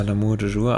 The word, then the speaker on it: L'amour toujours: L'amour toujours oder so? Spricht man so aus, oder? – Ja, L'amour toujours, aber war das der Song? – Ich glaube...